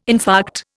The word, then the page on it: infarct: Indian